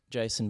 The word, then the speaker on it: Jacen: You can find me at Jason Bennett or at Jason Bennett Music, either one. My name is spelt J-A-C-E-N.